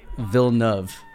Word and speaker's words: Villeneuve: Get some things off my chest about Villeneuve. Or Villeneuve. How do you say it? – Villeneuve. – Villeneuve.